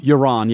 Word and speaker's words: Euron: Isn't it pronounced pretty much Euron? – Euron, yeah. – It's the same. Yeah. It's Euron.